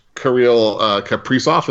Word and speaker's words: Kaprizov: I think they could make some hay in that division, and I'm excited to watch Kirill Kaprizov. Is that how you pronounce his name, Darren? – Yeah, that was pretty good. Yep.